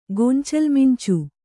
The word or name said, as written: ಗೊಂಚಲ್ಮಿಂಚು